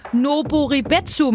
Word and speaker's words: Noboribetsu: Noboribetsu Marinepark Nikse på Hokkaido.